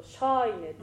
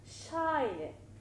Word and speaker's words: シャイネ: でどうしてもねアクセントを入れようとするとちょっと皆さんの傾向だとシャイネってちょっとね下ずりにこう落ちたくなるんですけど、シャイネどちらかというと下からこう突き上げて上の方に向かっていくような感じでアクセントを持っていただけるといいかなと思います。